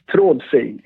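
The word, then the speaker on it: TRÅDFRI: Trodfi? How do I say them? – It's pretty close. Trodfi is the correct pronunciation, but I think I got it when you said it.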